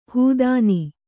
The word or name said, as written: ಹೆದ್ನ